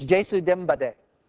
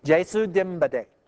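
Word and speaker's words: Jesu Dimbade (ကျေးဇူးတင်ပါတယ်): Here's how I spell it out so you can say it. It's Jesu Dimbade. That's how you say thank you. Jesu Dimbade.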